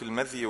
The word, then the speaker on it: المذي: المذي بفتح الميم وسكون الذال، وفيه أيضا ممكن ينطق المذي بفتح الميم وكسر الذال وتشديد الياء، يبقى المذي أو المذي، الاثنان صح، المذي والإيه والمذي. وفيه لغة ضعيفة جدا المذي بكسر الذال وتخفيف الياء، المذي مش المذي،